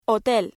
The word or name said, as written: hotel